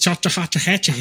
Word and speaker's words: Chattahoochee: It's Chattahoochee High School. – Oh, it is Chattahoochee. No, no, that's not. It's Chattahoochee. – That's Chattahoochee, like Alan Jackson song. – Yep. – Chattahoochee.